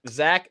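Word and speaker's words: Zac: I kind of feel that because, like, you know, Zach – my name's Zach, and I have obviously, like – it's like, extremely common name.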